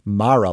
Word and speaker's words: Mara: More importantly, to the specific discussion here, Rooney Mara does in fact break from the family and chooses to pronounce her name as Mara, like tiara, which also happens to be closer to the traditional pronunciation of that Irish surname.